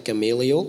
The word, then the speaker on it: Gamaliel: So, uh, so he modelled that he went through all this training under the Rabbi Gamaliel, Gamaliel, which uh, David, that's how you pronounce it, um, which David mentioned in, in his sermon.